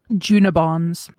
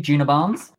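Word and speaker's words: Djuna Barnes: I'm going to say Juna Barnes. – Thank you, Juna Barnes.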